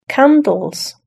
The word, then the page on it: candles: British English